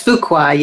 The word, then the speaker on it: Fuqua: It's Fuqua, yes.